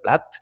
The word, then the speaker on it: River Plate: C'est un truc que je n'ai jamais su comment les Argentins vraiment le prononcer, si c'était Plate ou Plate. – Je crois que j'ai toujours entendu plate. – Plate. Il me semble bien. Ouais, ouais, c'est étonnant, il commence à l'anglaise presque.